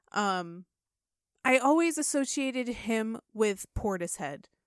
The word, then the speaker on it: Portishead: Portishead.